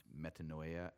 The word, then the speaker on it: metanoia: The Greek word that's translated repent is pronounced metanoia, and it means to change direction, to change our mind, to reorient ourselves.